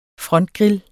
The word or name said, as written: frontgrill